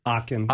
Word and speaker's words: Achan: Let's talk about Achan some more. Am I saying that right? – Achan. Yeah.